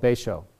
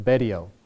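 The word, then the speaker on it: Betio: And in the bottom left corner is this little tiny island called Baisho or Baidio. People pronounce it differently.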